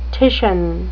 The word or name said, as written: titian